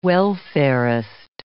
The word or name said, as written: welfarist